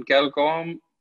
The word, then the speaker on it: Galgorm: Well, I've spoken to a couple of the Danish guys that play Challenge Tour on Galgorm. Do I say that correctly? – Yes.